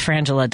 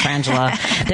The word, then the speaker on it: Frangela: He's like, it's Frangela. Don't say it wrong. It's Frangela. They're the comedy duo, and they're amazing.